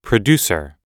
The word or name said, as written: producer